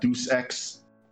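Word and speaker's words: Deus Ex: También el soundtrack de un juego que se llama Deuce X, eh, Human Revolution. Se escribe D-E-U-S espacio E-X y después Revolución Humana, Human Revolution.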